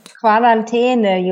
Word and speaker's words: Quarantäne: Quarantäne, you would say, yeah.